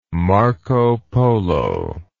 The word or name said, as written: marco polo